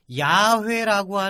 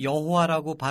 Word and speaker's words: 야훼: 얼마나 심하게 하느님의 이름을 안 불렀냐 하면 야훼라고 하는 이 단어 성경에 Y HWH 이렇게 써 있는 건데 이거를 어떻게 정확히 발음하는지를 발음법 자체를 까먹게 됩니다. 나중에는. 그래서 우리는 야훼라고 발음하는 게 옳다. 개신교 쪽에서는 여호와라고 발음하는 게 옳다.